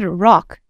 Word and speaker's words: Roch: So I went on to like a website where you pronounce it, and Roche, R-O-C-H, was, according to this website, pronounced Roche and then Thériault, something like that. – Oh, I just listened to it and it said rock.